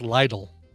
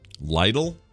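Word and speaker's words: Lidl: Oh, Bobby Frank says it's pronounced Lytle. – Lytle? – Lytle.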